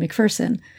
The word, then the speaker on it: McPherson: I want to do a quick shout out of thank you to the librarians in McPherson, Kansas, for helping me understand that, as they put it, there's no fear in McPherson. So that's a great way to remember how to pronounce that town.